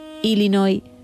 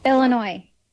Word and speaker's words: Illinois: Él, español, ella estadounidense, concretamente de Illinois, y fue quien grabó este audio para que escuchemos cómo se dice realmente. – Aquí te doy la muestra. – Illinois.